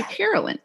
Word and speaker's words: Carolyn: As a woman named Carolyn and as a girl named Carolyn, spelled C-A-R-O-L-Y-N, your Ma character was called Caroline sometimes, and Michael seemed to call, or Pa called you Carolyn. Please, what did Ma prefer? What is the correct pronunciation? – Well, we don't really know, but on the show, it was supposed to be Carolyn.